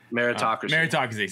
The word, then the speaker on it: meritocracy: I want to say metrocracy, but I can't say it right now. – Meritocracy. – Sorry, I was like, I'm missing a syllable.